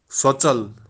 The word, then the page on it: sotsal: Kashmiri